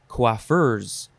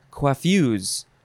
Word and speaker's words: coiffeuse: Oh, so you can pronounce this a few ways. You can say coiffeurs. Even though there's no R in there, you can say coiffeurs, or you can say coiffues, or coiffues. Fuzz? Fuzz? Anyway, it is spelled C-O-I-F-F-E-U-S-E.